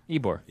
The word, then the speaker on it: Ybor: Wybor? – Ybor. – Ybor. – Yeah, I know that. But why it's spelled Wybor.